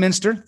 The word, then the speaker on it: Mynster: Um, but it's Diane Munster. Um, and I hope I said that correctly. It's Mynster. Mynster. See, I did not. Diane Mynster.